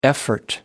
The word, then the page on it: effort: American English